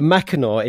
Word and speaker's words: Mackinac: But Mackinac is not spelled as it sound. It is M-A-C-K, and then it's I-N-A-C. So that's how you spell Mackinac.